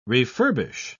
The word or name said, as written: refurbish